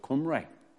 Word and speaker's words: Khumri: And Omri, they call it the house of, the Assyrians didn't call it the house of Omri, they called it the house of Qumri. That's how they pronounce it, K-H-U-M-R-I or something like that it was.